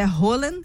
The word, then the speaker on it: Haaland: O jovem atacante norueguês Erling Haaland, que a gente chama aqui no Brasil de Haaland, né? Mas a pronúncia é Haaland.